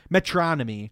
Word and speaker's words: Metronomy: Metronomy.